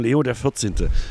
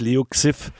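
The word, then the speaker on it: Leo XIV: Ich fand, Markus Barth hat gerade geteilt, dass man irgendein Ausschnitt einer Zeitung, wo steht Leo und dann XIV, und in Klammern wird ausgesprochen Leo der 14. Schrieb Markus Barth dazu. Das ist super für alle die bisher dachten, er heißt Leo Xiff.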